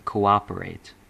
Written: cooperate

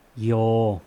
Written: yes

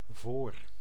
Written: voor

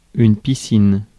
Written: bathroom

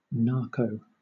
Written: narco

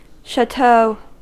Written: şato

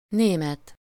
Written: allemand

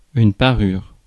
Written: ornament

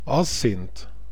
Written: asint

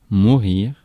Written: pass

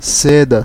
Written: seda

